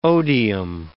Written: odium

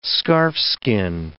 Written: scarfskin

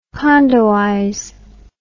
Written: condoize

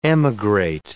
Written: emigrate

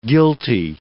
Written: guilty

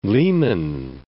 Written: Lake Leman

